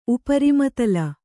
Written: ಉಪರಿಮತಲ

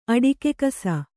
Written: ಅಡಿಕೆಕಸ

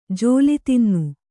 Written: ಜೋಲಿತಿನ್ನು

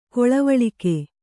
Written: ಕೊಳವಾಳಿಕೆ

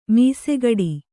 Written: ಮೀಸೆಗಡಿ

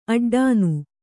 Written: ಅಡ್ಡನೆ

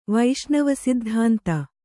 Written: ವೈಷ್ಣವಸಿದ್ಧಾಂತ

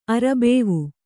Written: ಅರಬೇವು

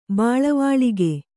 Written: ಬೆಳವಳಿಗೆ

